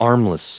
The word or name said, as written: armless